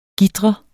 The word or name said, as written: GITRER